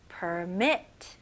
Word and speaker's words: permit: 네, 발음은요. per, 그 r 발음 잘 해주셔야 됩니다. per, 혀 당기는 느낌. permit. permit. okay? permit. okay, 강세가 어디에 들어갈까요? 네, 두 번째 음절이에요.